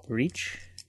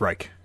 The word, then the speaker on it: Reich: It's pronounced Reich. – Is it Reich? – Yeah. – Hmm. – Third Reich. – I have a friend whose last name is Reich, spelt like Reich, but she pronounces it Reach. – R-E-I-C-H? – Yeah.